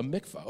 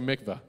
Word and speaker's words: mikvah: Now, I don't know if I'm pronouncing that right, so I don't want you all to follow in my folly. Elder Enos, am I pronouncing it right? A mikvah. Okay. We're good.